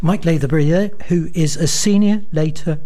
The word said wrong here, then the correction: Lathbury: Yeah, it's Lathbury. Lathbury. It's okay. No one ever gets my name right. Don't worry, Rob. I got called Lothbury the other day, and I said, I'm not a university.